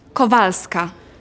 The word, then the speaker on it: Kowalska: D-O-R-O-T-A. – Thanks. What's your surname? – It's Kowalska. – And how do you spell that? – K-O-W-A-L-S-K-A. – K-O-W-A-L-S-K-A.